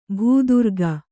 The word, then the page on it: ಭೂದುರ್ಗ: bhū durga